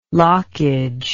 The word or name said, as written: lockage